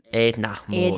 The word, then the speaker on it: Edna Mode: Edna Mode.